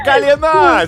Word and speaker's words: колено: Правда, на колено здесь, а не на колено.